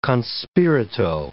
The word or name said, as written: con spirito